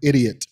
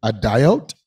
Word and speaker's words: idiot: I remember very distinctly encountering the word idiot in a book, and I pronounced it a diote.